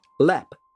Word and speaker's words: Leap: Oh, by the way, Lepp is spelt as in leap, L-E-A-P, going back to its original meaning, but it is pronounced as Lepp.